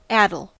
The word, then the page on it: addle: American English